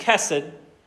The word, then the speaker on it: chesed: This word in the Hebrew is pronounced chesed.